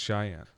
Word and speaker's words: Cheyenne: Cheyenne. – I've never been there. I don't know how it's pronounced. – Yeah, I've never been there either, but yeah, it's Cheyenne.